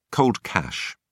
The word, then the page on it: cold cash: British English